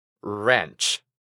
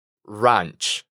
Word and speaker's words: ranch: Podemos decirla con una A relajada, ra, ranch. Esta pronunciación es más común escucharla en Estados Unidos. También podemos pronunciarla más abierta y parecida al español. Ra, ranch. Esta segunda sería más común en Reino Unido.